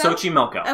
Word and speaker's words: Xochimilco: I can't pronounce it, it has an X in it. – Xochimilco. – Xochimilco? – It's Xochimilco.